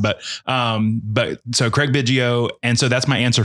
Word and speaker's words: Biggio: Biggio.